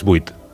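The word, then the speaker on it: vuit: 8 es buit.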